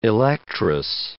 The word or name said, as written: electress